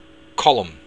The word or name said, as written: colm